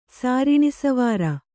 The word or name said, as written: ಸಾರಿಣಿಸವಾರ